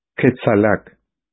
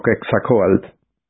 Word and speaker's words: Quetzalcóatl: El gran jefe me dijo que te llamas Quetzalac. No, lo pronuncian mal. Me llamo Quetzalcóatl.